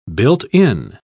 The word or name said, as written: built-in